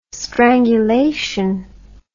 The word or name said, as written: strangulation